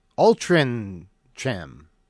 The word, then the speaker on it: Altrincham: So Altrincham is pronounced Altrincham. Pronounce the C like a G.